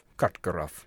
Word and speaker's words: Karkaroff: Karkaroff? – Karkaroff.